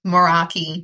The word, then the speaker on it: Meraki: How do you say it? Meraki Holistic Healing or Meraki? – Oh, Meraki. Yeah, it's a Greek word that means to do something with your heart and soul.